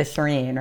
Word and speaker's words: Shirin: Twitter, Instagram at The Shirin, right? – Yep. The Shirin, and it's spelled S-H-I-R-I-N.